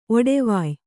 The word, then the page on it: ಒಡೆವೊಯ್: oḍevoy